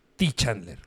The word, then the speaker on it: Ty Chandler: ¿Es, es T.Y. Chandler o Ty Chandler? T. Chandler. T. Chandler. No, no te es, es que no sabía cómo pronunciarlo.